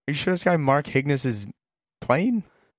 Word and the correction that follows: Markakis: Nick Markakis, yes.